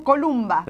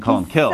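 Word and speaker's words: Colm Cille: That's the English pronunciation. – Esa es la pronunciación inglesa. – Or Columbkill. – O se podría decir también en española. O la otra forma es Columbkill. – Maybe it's better to use the Gaelic pronunciation, Columbkill.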